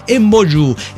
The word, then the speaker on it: emboyú: En otomí se le dice emboyú. Se pronuncia así, pero se escribe embo, emboyú.